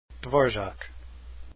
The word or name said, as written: dvorak